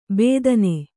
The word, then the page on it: ಬೇದನೆ: bēdane